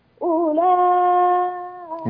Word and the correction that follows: أولئك: لا لا يا إدريس، أولى مش أولئك، ما فيش واو يا ابني، هذه الواو لا تنطق. أولئك.